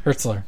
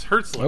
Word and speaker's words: Hertzler: Maybe it was a J.G. Heltzer in... Hertzler? Impersonator. – Hertzler. – Yes, Hertzler. Oh. I've been pronouncing it wrong for ages.